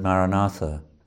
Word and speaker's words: Maranatha: After the music and the singing, start to say the word Maranatha as four separate syllables, Ma, Ra, Na, Tha.